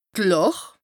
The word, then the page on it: d' Làch: Bas-Rhin; Strasbourg